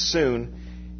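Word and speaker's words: soon (σύν): The prefix is the word soon. It's spelled like sun, but it's soon.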